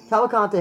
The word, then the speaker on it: Cavalcante: Cavalcante. – Cavalcante hasn't fought.